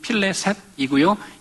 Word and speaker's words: 블레셋: 블레셋의 원래 발음은 필레셋이고요.